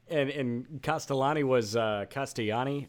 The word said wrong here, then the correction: Castellini: I don't know how to say his last name. – Castellini. – Castellini? Is that right?